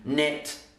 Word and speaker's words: knit: So keep, knit, kitten, and kind. Remember, K before N is usually silent. Knock, knob, for example, those all have a silent K.